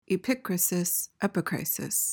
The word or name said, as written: epicrisis